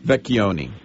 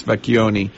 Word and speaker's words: Vecchioni: Vecchioni. So with a hard C. Patrice Vecchioni.